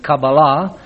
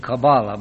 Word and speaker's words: Kabbalah: And in so doing, let me say to begin with that you will probably hear at least two different pronunciations of the word Kabbalah. I'm almost certain that Professor Walton will be pronouncing it Kabbalah, because that is the Hebrew pronunciation, but both Dr. Owens and myself are using the anglicized pronunciation Kabbalah, but just to be sure, we are both talking about the same thing.